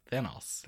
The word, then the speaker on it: Thanos: How do you say it? – Thanos.